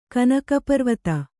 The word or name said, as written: ಕನಕಪರ್ವತ